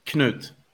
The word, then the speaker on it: Knut: Knut, with a K. – Okay. – The K is not silent.